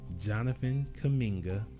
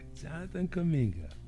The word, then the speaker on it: Jonathan Kuminga: So now we gonna move on to the next guy, which is number 7 to the Warriors, Jonathan Kuminga. And this guy was nice, man. – You said his name right. – Yeah. I definitely got that one. – Jonathan Kuminga.